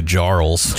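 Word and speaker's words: Jarle: It's J-A-R-L-E. – Oh, Yarly. – I always say Jarls.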